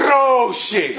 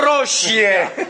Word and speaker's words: roșie: And she says, roșie. – Și spune, roșie. – Roșie, okay. – You're still not saying it right now.